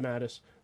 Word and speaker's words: DeMatteis: Jan DeMatteis? Is yeah, that how you say it? – DeMatteis.